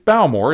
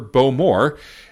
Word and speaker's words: Bowmore: I pronounced it Bowmore instead of the proper Bowmore.